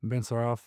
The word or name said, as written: Ben Saraf